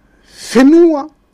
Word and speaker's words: Fenua: Donc, Fenua, il n'y a que les trois îles du sud qui le prononcent le F. Et dans le sud, dans le nord, Henua, le H. La lettre F ne se prononce uniquement que dans le sud des Marquises.